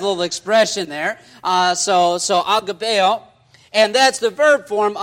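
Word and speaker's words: agapaō: And so in the Greek, it is agapeo, okay? Or, Brother Larson, am I saying that right? Okay, I'm saying it right.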